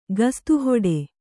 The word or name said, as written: ಕುಸ್ತಿಹಿಡಿ